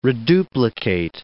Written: reduplicate

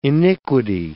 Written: iniquity

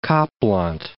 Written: Kaapland

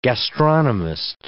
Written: gastronomist

